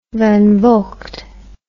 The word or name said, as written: Van Vogt